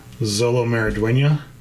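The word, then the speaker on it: Xolo Maridueña: I don't know a lot about Jaime Reyes, which is who, I really hope I don't butcher this, Zolo Maridueña. – I think you did a good job.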